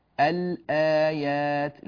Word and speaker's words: الآيات: الهمزة مثل كلمة الآيات، الآيات، لاحظوا أننا أظهرنا اللام فقلنا الآيات، وليس كما ندغمها مثلا الطام، الط، فننتقل إلى الطاء مباشرة ولا يوجد نطق للام مظهرة، يعني اللام منطوقة.